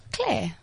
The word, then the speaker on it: Claire: So how do you say your name? – Claire.